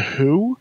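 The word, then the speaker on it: Hu: Tell me about this band called The Who, but it's spelled H-U, and they're a band that uses just Mongolian instruments, and they're fucking fantastic.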